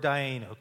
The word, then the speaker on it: Dayenu: The English letters we use to spell that is D-A-Y-E-N-U. Dayenu. Can we all say that together?